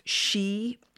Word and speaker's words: Sith: And also their newest spin of She, which is spelt S-I-T-H, that's going to be available in double knit, Aran and 4-ply.